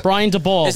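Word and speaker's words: Brian Daboll: So for Brian Dabble, what do we do? – DeBall. Brian DeBall.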